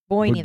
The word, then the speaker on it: Boyne: They are from the family-owned Bow and Distillery, which is found in Ireland's Bone Valley. And I am sure I'm saying that wrong. Boyne? Boyne. – Boyne. – Boyne. Boyne Valley.